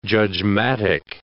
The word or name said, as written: judgmatic